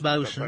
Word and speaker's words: boatswain: Boatswain, that's bosun.